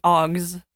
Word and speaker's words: aux: It's supposed to be augs, like the G.